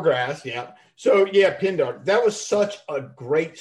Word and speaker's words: Pindar: I mean, it's Pendergrass, right? Did I say it right? Pendergrass? – What's that? Pendergrass? – Isn't that the guy's name? Pendergrass? In the one of the books? – Pindar. Pindar. – Pindar. Pindar. Yeah.